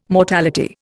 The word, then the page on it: mortality: Indian